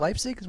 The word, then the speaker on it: Leipzig: Is it Leipzig? Am I saying that right? – Yeah, I think you're nailing it.